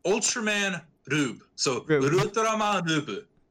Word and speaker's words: R/B: It's pronounced Ultraman Rube. But as the way Japanese works is the L's and the R's are the same letters.